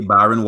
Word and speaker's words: Byron: Okay, social media is officially Byron Walker on Instagram, officially Byron Walker on Facebook. On Twitter, it's officially Byron. And my name is B-Y-R-O-N. It's spelled the way it sounds.